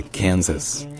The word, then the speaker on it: Kansas: Dans Kansas, en fait, l'accent tonique est sur le can, donc c'est Kansas.